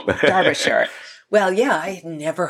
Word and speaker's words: Derbyshire: How do you say it? Derbyshire or Derbyshire? – Oh, I think it depends which part of England you're from. Oh, I say Derbyshire.